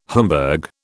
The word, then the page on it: Homburg: British English